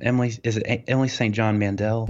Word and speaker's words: Emily St. John Mandel: Emily St. John Mandel? – That's it.